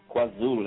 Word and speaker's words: KwaZulu: And this song is entitled, now, tell me if I'm pronouncing it right, KwaZulu. – KwaZulu, that's the one.